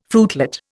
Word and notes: fruitlet: Indian

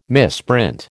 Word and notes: misprint: American English

